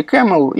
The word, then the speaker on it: Caml: современное слово Caml или camel угу. – произносится по-английски camel и, собственно, все эти аллюзии на верблюдов.